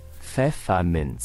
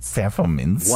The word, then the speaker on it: Pfefferminz: Let's say it all together. – Okay. – Pfefferminz. – Pfefferminz. – One more time. – Pfefferminz.